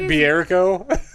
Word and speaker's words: Bierko: Bierko? – Yeah, I know.